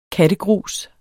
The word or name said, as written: kattegrus